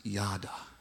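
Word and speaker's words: yada (יָדַע): This, the word new that we just read is pronounced yada. It reminds me of dada.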